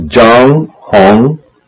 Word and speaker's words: Zhang Hong: My name is Zhang Hong. – Will you spell it? – Okay. Z-H-A-N-G. H-O-N-G.